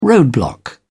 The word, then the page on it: roadblock: British English